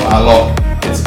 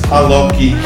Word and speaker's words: Alok: Even though it's spelled A-L-O-K. The Brazilians have this cute little habit of putting a schwa on the end, so it's not Alok, it's Aloki. Aloki.